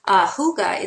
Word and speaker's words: hygge: Huga, it's spelled H-Y-G-G-E. So it looks like Hygge or Hyg, but it's actually pronounced Huga. It's a Danish word.